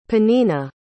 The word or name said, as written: penina